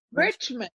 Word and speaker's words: Riechman: Riechman.